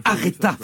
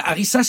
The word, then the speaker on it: Aretha: Arrête-toi Mishka, elle se nomme Aretha Franklin. Arissa c'est une putain de purée de piment.